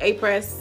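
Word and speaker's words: Apres: I do like the Aprix. Is that how you say it? A-press. It's A-press.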